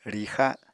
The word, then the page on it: rixa: Apatani